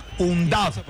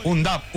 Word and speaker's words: Undav: Un Duff. Se escribe sin H. Un Duff. – Adelantado. – Muy claro. – Muy bueno. Es decir, como UNAT, pero no. – No. – UNDAV, UNDAV, UNDAV.